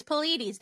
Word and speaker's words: Pleiades: I am so in my head about how to pronounce Pleiades. I think it's Pleiadians. It's Pleiadians, but I always forget how to say Pleiades. It's Pleiades.